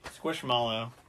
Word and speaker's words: Squishmallow: We went and we checked it out and we got a squishimal. Am I saying that right? – Squishmallow.